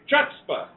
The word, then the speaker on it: chutzpah: The word is spelled C-H-U-T-Z-P-A-H. Now then, I'm going to go around the table. How do you say that word? – Chutzpah.